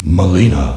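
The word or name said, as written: Mileena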